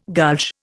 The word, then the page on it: gulch: Indian